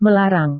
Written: melarang

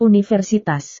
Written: universitas

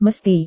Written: mesti

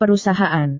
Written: perusahaan

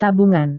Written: tabungan

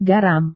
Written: garam

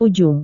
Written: ujung

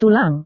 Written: tulang

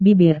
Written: bibir

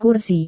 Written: kursi

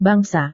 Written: bangsa

